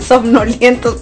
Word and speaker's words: somnolientos: ahí andamos todos soñoluentos. ¿Cómo se dice? Somnolientos, perdón. Estoy como el Chavo del Ocho, ¿no?